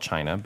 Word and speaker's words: China: C-H-Y-N-A? – No, it's C-H-I? – Her name is actually pronounced China. – Uh-huh. – There's a dash between the N and the A, but it's, you know, spelled China, but China.